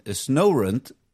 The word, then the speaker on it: Snorunt: Als je gaat kijken in de originele Pokémon games, dan hebben Vulpix, Poliwag, Hippopotas en Snorunt, zeg ik dat goed? Ja. Die hebben, Snover, die hebben de ability om het weer aan te passen.